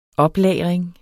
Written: OPLAGRING

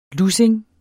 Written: lussing

